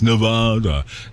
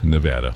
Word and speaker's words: Nevada: People can't stop saying Nevada. You going to Las Vegas? Nevada. No, it's Nevada, for crying out loud.